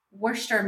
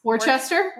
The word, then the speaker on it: Worcester: Worcester. – I love when people say Worcester. Worcester. I'm like, it's not even spelled that way. I yeah. get that it's weird, but it's not spelled like that.